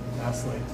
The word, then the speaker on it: Vasily: Vasily. – Vasily. – Not silly. Vasily. – Not silly, Vasily. – There's the T silent also.